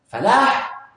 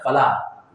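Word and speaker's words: فلاح: Falah is with a ha. Make sure we pronounce it correctly. Not falah.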